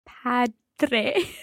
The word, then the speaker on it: padre: padre. – Let's hear padre. – Padre. – Okay, it's a strong D in there. And just like dormire, padre.